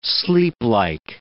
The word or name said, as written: sleeplike